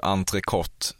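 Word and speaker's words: entrecôte: Det är bara ren tur att du uttalar entrecote rätt. – Entrecote. – Du säger entrecote? – Absolut. Ja. Jag skulle inte säga entrecote.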